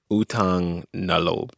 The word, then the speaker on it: Utang na loob: Utang na loob. – Utang na loob. Did I say that right? – Yes.